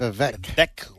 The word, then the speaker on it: Vivek: I always get confused on the first name. It's Vivek. – It rolls off your tongue. – Vivek Ramaswamy.